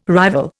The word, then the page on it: rival: Indian